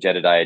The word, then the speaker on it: Jedidiah: It's just my name, Jedediah Jenkins, like spelled like Jedi Dia.